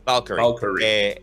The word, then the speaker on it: Valkyrie: Soccer Punch Productions y Valkyrie, ¿cómo se dice? Valkyrie. – Valkyrie.